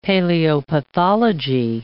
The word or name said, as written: paleopathology